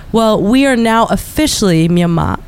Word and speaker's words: Myanmar: And what a lot of European language speakers don't hear is that the Burmese pronunciation of the country is actually Myanmar. And Myanmar and Burma sound much more similar than Myanmar, Myanmar, and Burma, right?